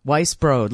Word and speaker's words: Weisbrod: In a very, very close match, Kelly Krull and I took down Mark Harmon and Brooke Weisbrod by a score Weisbrod, like Weissbeer, and Brode rhymes with road.